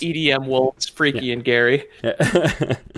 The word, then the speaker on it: Geri: So Freki is spelled F-R-E-K-I. Could easily be Freki. And Gary is spelled G-E-R-I. – Oh, yeah. – So make that what you will, but I think it's Gary.